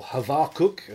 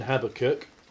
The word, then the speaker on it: Habakkuk: So, as we can probably tell, we're going to look at the book of Habakkuk, or Havakuk, apparently, if you try and pronounce it in remotely in any sort of Jewish way. But we'll stick with an English pronunciation, Habakkuk, which, does anyone happen to know what the word, what his name means by any chance?